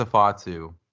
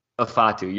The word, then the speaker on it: Ifeatu: And Ifeatu Melifonwu, and remind me how to pronounce his first name. – I think it's Afatu. – Afatu. You're correct.